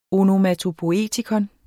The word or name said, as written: onomatopoietikon